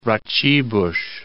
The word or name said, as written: Raciborz